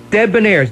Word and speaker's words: Debonairs: The Debonairs, now that's Debonairs, Debonairs, actually, D-E-dash, B-O-N-A-I-R-S, on Ping Records, 1956, with Cracker Jack Daddy, that's pronounced Debonairs.